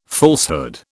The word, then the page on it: falsehood: British English